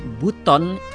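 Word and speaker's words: Buton: Chers amis, de la capitale de Bouton, c'est la prononciation de l'Indonésie, c'est Bouton. Mais pour la français peut-être, on l'appelle Bouton.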